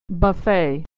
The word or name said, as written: buffet